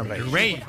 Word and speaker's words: Raye: Ray. Ray. Se escribe Raye.